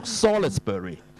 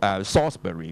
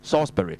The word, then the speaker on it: Salisbury: ，L 字唔發音嘅，你激唔激死啊？你唔好讀 Salisbury，、 oh. 其實咧喺英國咧有一個平、oh. 有笪地方係叫做 Salisbury 嘅，Salisbury。咁而家有一個平原叫 Salisbury Plain 嘅。